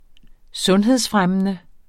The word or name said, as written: sundhedsfremmende